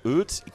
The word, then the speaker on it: Oete: On va retrouver Eut, qui s'écrit O-E-T-E, mais qu'on prononce Eut, qui est absolument formidable.